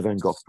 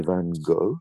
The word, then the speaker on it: Van Gogh: I, I should say the, the, British say Van Gogh, by the way. You guys say Van Gogh. – Van Gogh. We say Van Gogh.